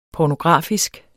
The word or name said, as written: pornografisk